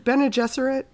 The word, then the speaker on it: Bene Gesserit: is it Bene Gesserit? – Yes.